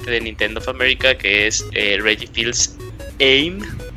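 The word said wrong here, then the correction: Aimé: en el cual pues... Se pronuncia AME. – es AME. – Ame.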